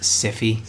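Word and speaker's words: Syfy: This is sci-fi again, right? – Yes. – Okay. – Bob, yeah. It's pronounced Siffy. – Siffy, sorry. Sorry, I don't even know what I was thinking there. I don't know what you're thinking, but you should pronounce it correctly.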